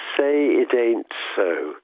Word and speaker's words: Say It Ain't So: C'est Say It Ain't So. It ain't, c'est un arbre, c'est comme l'argot, c'est pas, it ain't, c'est, ça veut dire, ce n'est pas.